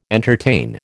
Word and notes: entertain: American English

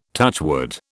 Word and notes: touchwood: British English

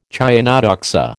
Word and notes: chionodoxa: American English

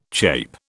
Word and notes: chape: British English

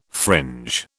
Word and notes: fringe: British English

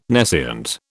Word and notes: nescient: British English

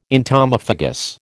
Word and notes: entomophagous: American English

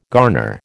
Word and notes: garner: American English

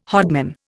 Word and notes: hodman: Indian

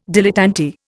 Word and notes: dilettante: Indian

